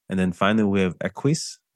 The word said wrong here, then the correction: Esquisse: I'm probably pronouncing that wrong. It's my French is bad. – Esquisse. – Okay, there you go.